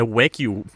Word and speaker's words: Wagyu: Og du er jo ejer af Wagyu Bornholm. Jeg kunne forstå det. Ja, sådan man udtaler det.